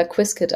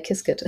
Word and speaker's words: Qiskit: Es gibt noch die internen Challenges, ob es Quiskit oder Quizkit ausgesprochen wird, aber ich bin sozusagen die äh, Partei Quiskit.